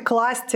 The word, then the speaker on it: класть: Э, торты, класть. Э, это правильные слова, неизвестные этому эфиру до сегодняшнего дня.